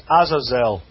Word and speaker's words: Azazel: The Hebrew word is Azazel. We would spell it in English A-Z-A-Z-E-L.